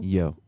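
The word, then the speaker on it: Yeoh: Yo. – Yo. – Yo. Michelle, yo. – Sweet. Sounds good. – It's Y-E-O-H. So I think that's correct.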